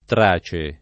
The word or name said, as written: tracio